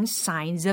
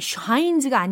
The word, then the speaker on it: signs: His shoes are showing signs of age. 이렇게 읽어야 되거든요. 연습을 제대로 하고 계신다면 입이 자꾸 오므라들어요. 그죠? His shoes are showing. 그 다음 shines가 아니고 signs of age라는 것. 발음에도 유의하시면서 연습하시면 좋겠습니다.